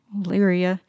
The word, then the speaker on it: Olearia: Macintosh has very few Daisy players to choose from, and this is one called Oleria. Olearia, O-L-E-A-R-I-A. I believe that's how you pronounce it. – That's correct.